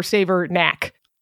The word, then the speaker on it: Savernake: But no, it definitely ends in knack. So Saver or Savernake.